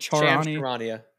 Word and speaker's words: Charania: Isn't it Charania? – Charania.